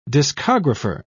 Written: discographer

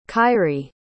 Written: kyrie

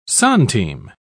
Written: centime

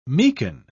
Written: meeken

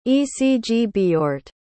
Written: ecgbeorht

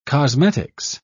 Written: cosmetics

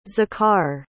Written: Zakhar

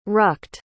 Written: rucked

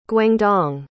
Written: guangdong

